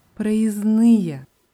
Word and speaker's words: проездные: Bei dem Wort проездные ist es noch wichtig zu sagen, dass da zwischen dem S und N ein D steckt, wird aber nicht ausgesprochen, also geschrieben проездной oder in der Pluralform проездные, aber ausgesprochen проездной, проездные.